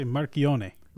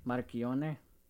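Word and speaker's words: Marchionne: I'd say Marchione. – Marchione.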